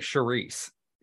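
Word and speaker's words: Saoirse: I think it's pronounced like Charisse.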